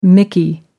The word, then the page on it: mickey: American English